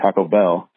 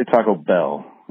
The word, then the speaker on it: Taco Bell: I'm going to look past your terrible slander of Chicago-style pizza, but I got a question about your pronunciation of, as you call it, Taco Bell, with the emphasis on taco, which seems wrong to me. Should be on the bell. Should be Taco Bell, like Taco Jones and, you know, Taco Leroy's or whoever.